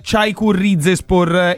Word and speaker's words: Çaykur Rizespor: Io ancora ci spero, però insomma forse è un po' tardi, ma soprattutto 3 anni, e ora gli do il buongiorno e gli chiedo se la pronuncia è giusta, nel Çaykur Rizespor in Turchia. Davide Petrucci, buongiorno e benvenuto su Radio Firenze Viola, grazie per essere con noi. – Buongiorno a voi, buongiorno a voi. Sì, la pronuncia è esattissima.